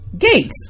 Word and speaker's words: Gaetz: And we're just going to jump right into politics, starting with Mr. Gaetz.